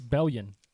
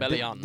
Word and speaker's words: Bellion: John Bellion. – It's Bellion. I-O-N at the end. – Bellion.